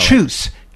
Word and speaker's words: Chus: Chus, C-H-U-S, Pato, P-A-T-O.